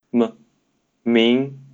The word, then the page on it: 明: Mandarin Chinese; male